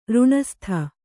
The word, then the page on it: ರಿಣಸ್ತ: riṇasta